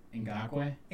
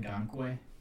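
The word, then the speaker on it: Ngakoue: Ngakwe? Ngakwe.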